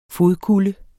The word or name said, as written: fodkulde